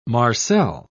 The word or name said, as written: marcel